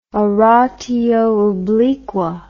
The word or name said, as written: oratio obliqua